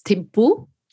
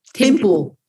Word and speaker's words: Thimphu: Is it Thimphu? What's the capital of Bhutan? – Yes, it's Thimphu. Thimphu.